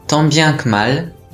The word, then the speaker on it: tant bien que mal: tant bien que mal, tant bien que mal と発音します。 tant そして bien は微母音なので気をつけましょう。